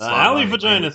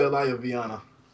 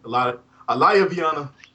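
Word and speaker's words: Alaya-Vijnana: The Alley Vagina system. – No, it's the Alayaviana. That's it. Alayaviana system.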